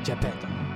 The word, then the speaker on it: Geppetto: Se dice así, Geppetto.